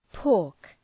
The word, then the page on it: pork: pɔ:rk